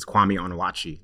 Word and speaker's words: Kwame Onwuachi: It's Kwame Onwuachi. That's how they pronounce it.